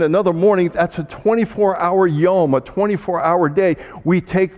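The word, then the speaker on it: yom (יום): In the creation account, Moses uses a specific word to describe the 6 days, D-A-Y-S, the 6 days of creation. And he uses this word in the Hebrew, yom. We would pronounce it in English, Y-O-M, yom.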